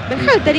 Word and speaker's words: Berhalter: ¿Cómo es el apellido? – ¿Cómo? – Dígalo usted. – Berhalter. – ¿Y qué dije yo? – No, impecable.